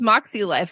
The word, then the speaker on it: Moxie Life: I just pronounced Moxie Life because it's supposed to be like Moxie. The phonetic spelling of Moxie.